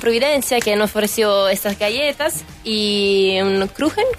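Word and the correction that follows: Kuchen: ¿cómo lo llaman ustedes? ¿Crujen? – Cujen. – Cujen, cujen. – Ah, no se dice, claro, se trae. En Chile como que no se españoliza tanto eso.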